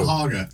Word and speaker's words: Cuyahoga: Which again doesn't look like how it's pronounced. – That's true. – Cuyahoga.